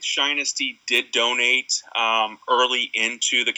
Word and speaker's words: Shinesty: Shinesty.